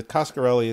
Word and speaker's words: Coscarelli: This one is the Coscarelli. It's pronounced as it's spelled.